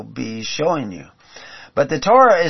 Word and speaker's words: Torah: In the Hebrew, we see this word that we pronounce as Torah, as the letters of Tav, Vav, Resh, He.